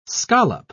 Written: scallop

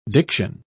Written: diction

